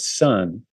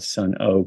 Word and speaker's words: Sunn: called Sun. That's it's spelled S-U-N-N, and then it has this weird symbol behind it. People sometimes think they're called Sun O because it looks like a sun.